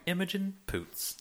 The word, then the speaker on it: Imogen Poots: Imogen Poots over and over and over again.